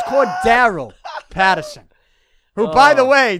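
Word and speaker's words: Cordarrelle: If you look at the Patriots If you look at the Patriots, the yearbook or whatever they have, the media guide, they have the pronunciation. Go to Pro Football Reference. The pronunciation's there. It's going to say Cordero.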